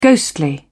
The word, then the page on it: ghostly: British English